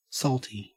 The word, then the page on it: saltie: Australian English